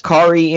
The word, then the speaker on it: Kaare: And we're pronouncing it Kari Andrews. If there's another pronunciation that we don't know about, I apologize ahead of time. – Yeah. All right. – The internet told me it rhymes with Atari, so do with that what you will.